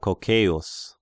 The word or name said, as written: Cocceius